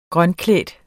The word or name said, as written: grønklædt